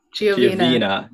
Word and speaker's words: Giovina: I butchered your last name after I asked you how to pronounce it. – Giovina. It was close enough.